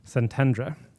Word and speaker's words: Szentendre: Szentendre.